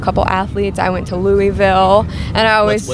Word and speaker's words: Louisville: Well, it's Louisville, but however you're supposed to say it, one less syllable.